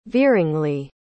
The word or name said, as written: veeringly